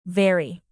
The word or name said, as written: very